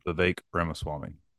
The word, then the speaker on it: Vivek Ramaswamy: And also, how do you say is Vivek? Vivek Ramaswamy? How do you say the guy's full name? – Vivek. – Mm-hmm. – Vivek Ramaswamy.